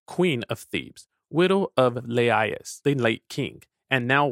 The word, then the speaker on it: Laius: Laius, pronounced as three syllables, Laius, the last king of Thebes before Oedipus.